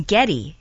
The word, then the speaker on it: Geddy: And when Gary was a teenager, she used to say his name, Geddy. Geddy, Geddy, you know, she couldn't pronounce Gary in her Polish accent,